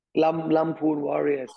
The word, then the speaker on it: Lamphun Warriors: Lumpur Warriors.